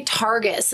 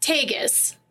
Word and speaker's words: Tagus: And I always want to say Targus, and there's no fucking R in it. – Targus. – And it's literally... – like Fargus. – Tagus.